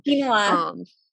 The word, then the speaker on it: quinoa: Is it Keanu? I don't know. I probably say that wrong. – Quinoa. It's okay.